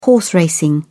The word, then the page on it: horse racing: British English